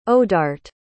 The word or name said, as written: odart